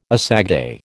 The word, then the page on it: assagai: American English